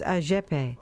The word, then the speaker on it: Agepe: That's Brazilian singer Antonio Gilson Porfirio, known as Agepe. He took his nickname from the phonetic pronunciation of his initials, A-G-P.